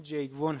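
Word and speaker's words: Jaegwon: به نظرم میرسه که تلفظ درستش همونی که تو این کتاب به کار رفته، یعنی جیگ وون هست.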